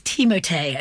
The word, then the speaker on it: Timothée: It is Timothée Chalamet. – Oh, wow. – It is like the 1980s shampoo.